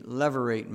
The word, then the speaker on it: levirate: Therefore, there was the custom of the levirate marriage. Am I pronouncing that right? Levirate marriage. Although it's spelled L-E-V-I-R-A-T-E, it has nothing to be associated with Jacob's son Levi.